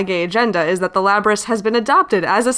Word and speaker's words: Labrys: All signs point to it being pronounced Labrys.